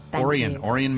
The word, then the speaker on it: Orion: Orion, Michigan.